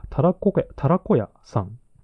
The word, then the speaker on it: タラコ小屋: 次、オノ 27C、タラコ小屋、タラコ屋さんですね。タラコ屋。ひらがなでタラコって書いて、漢字で小屋って書いて、タラコ屋って読むみたいです。